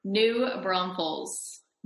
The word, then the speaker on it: New Braunfels: New Braunfels.